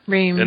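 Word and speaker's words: Reamde: How do you pronounce that title? – Reamed.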